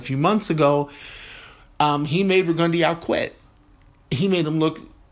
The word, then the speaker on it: Rigondeaux: And in Lomachenko's last fight against, now it's funny, it was Rigondeaux is how it's being pronounced now on TV. Jim Lampley, I'm going to rely on Lampley to say Rigondeaux.